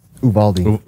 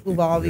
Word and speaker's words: Uvalde: And I don't know how to pronounce the name of the city in Texas. – Uvalde. – Uvalde. Yeah. So Uvalde, Texas.